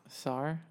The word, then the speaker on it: czar: No, it's pronounced czar, bro. – Czar? – Czar.